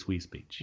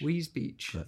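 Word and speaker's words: Wisbech: Am I saying it right, Wisebeck? – I think it's Wisebeck. – Wisebeck, thank you.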